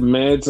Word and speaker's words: Mads: That doesn't mean, is it pronounced Mods or Mads? – They said Mads.